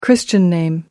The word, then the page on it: christian name: American English